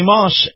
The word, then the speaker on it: Maus: Now, Moss is the largest selection in the state right now, and before you buy elsewhere, check them out. See Moss, M-A-U-S is how you spell it, M-A-U-S.